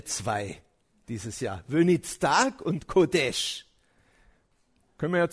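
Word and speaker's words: Kodesh (קודש): Wenitztag, Kodesh. Kodesh. Wenitztag, Kodesh. Übrigens, nicht, dass ihr es dann so schreibt, so würde es keiner schreiben, der Hebräisch kann, aber so schreibt man es, damit man die Aussprache hinkriegt.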